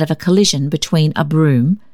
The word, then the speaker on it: brougham: spelt B-R-O-U-G-H-A-M, and an omnibus. One of the advocates pronounced the word brougham as a dissyllable, evidently unaware that the name of the vehicle, like the surname of Lord Broom, is abbreviated into a monosyllable.